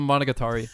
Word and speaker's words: Monogatari: Monogatari? Is that right? – Yeah. Iatovo Monogatari. Monogatari.